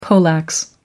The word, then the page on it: poleaxe: American English